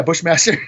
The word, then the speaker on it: Bushmaster: Bush, yeah, Bushmaster.